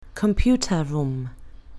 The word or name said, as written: computer room